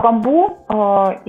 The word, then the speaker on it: Bamboo: Еще один продукт, который называется Bamboo. Правильно я произношу? Потому что его по-разному. – Да, Bamboo.